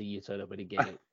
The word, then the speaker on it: gi: Gi, what's it called? Is it a gi? – It's spelled G-I, but it's pronounced gi.